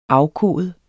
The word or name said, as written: afkode